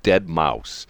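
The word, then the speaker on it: deadmau5: but the guy's called Dead Mouse, but it's spelled M-A-U-S.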